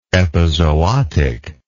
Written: epizootically

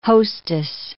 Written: hostess

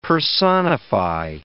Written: personify

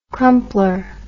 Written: crumpler

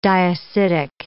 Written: diacidic

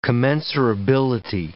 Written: commensurability

